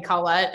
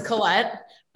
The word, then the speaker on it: Colette: My husband has always called me Colette, and it wasn't until my brother told him, that's not how you say your name. I'm just used to people pronouncing it wrong. So it's Colette, but you can pronounce it however you feel.